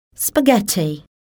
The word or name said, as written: spaghetti